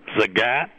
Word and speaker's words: Zagat: it is Zagat, like the cat in a hat, but you can pronounce it any way you like as long as you buy it.